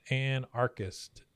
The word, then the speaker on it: anarchist: Between Dale's wild and Antarctic. How do you say that? Anarchist. He's an anarchist.